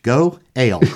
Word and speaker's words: goel: In Hebrew, guardian redeemer, he's the goel for the beer drinkers out there, that's pronounced go ale.